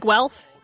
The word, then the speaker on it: Guelph: Is it Guelph? G-U-E-L-P-H? – It is indeed.